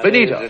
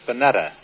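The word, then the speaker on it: Bennetta: Benita. – It's pronounced Benetta. – Benetta. I see, sir.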